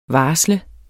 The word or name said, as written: varsle